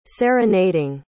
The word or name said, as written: serenading